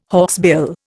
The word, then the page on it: hawksbill: Indian